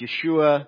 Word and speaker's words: Yeshua: Would have been pronounced Yeshua.